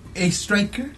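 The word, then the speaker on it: Oestreicher: Muestra la pantalla, por es como, yo lo pronunciaría, yo lo pronunciaría como Oi Striker. Oi Striker. – Pero él lo pronuncia como A Striker.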